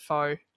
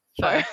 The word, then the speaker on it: pho: For those in countries that don't have a huge Vietnamese population, you guys probably pronounce it pho. It's pho is how you correctly pronounce it.